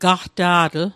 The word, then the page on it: gaqta'tl: Mi'gmaq